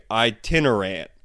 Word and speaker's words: itinerant: But when I was reading it, I was like, iterant? Iterant. It became iterant in my head, and I had to slow down and look at these notes and spell it to say it correctly. Itinerant.